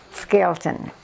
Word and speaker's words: Skelton: Catherine, K-A-T-H-R-Y-N, Ann, A-N-N, Skelton, S-K-E-L-T-O-N.